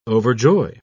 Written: overjoy

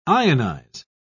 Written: ionize

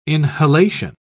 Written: inhalation

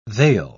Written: they'll

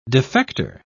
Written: defector

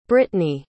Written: brittney

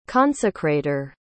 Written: consecrator

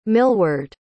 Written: milward

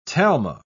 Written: talma

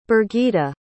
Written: birgita

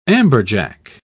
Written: amberjack

